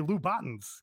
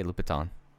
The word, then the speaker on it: Louboutin: Americans say Lubatins because they don't. No French. – I was talking about at the time. – Yeah, I say Louboutin.